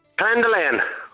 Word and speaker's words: Clondulane: Right, and is it Clondaloon or Clondaloon? – Clondaloon. – Clondaloon, right. – Clondaloon, yeah. – Clondaloon. How do you spell it? – C-L-O-N-D-U-L-A-N-E. – Aha, Clondaloon. I had it wrong there.